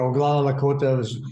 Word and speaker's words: Oglala Lakota: the Oglala Lakota. – Oh yes, you are. Oglala Lakota is perfect.